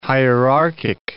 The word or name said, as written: hierarchic